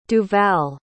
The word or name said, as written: duval